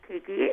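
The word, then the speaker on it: Caiikie: So my name is Kaikie. It's C-A-I-I-K-I-E.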